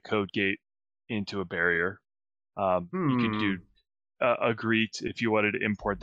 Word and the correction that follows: Egret: Egret. – Egret. I always say that wrong.